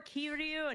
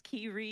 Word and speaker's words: Kiryu: So I just, like, or Kiryu and Kiryu. I'm like, no, it's Kiryu. – Oh. – You know, I emphasize so on the R.